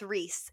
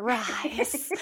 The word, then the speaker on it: Rhys: I almost pronounced it super wrong, but I recently found out that if you spell a word R-H-Y-S, that's Reese. It's not Rice.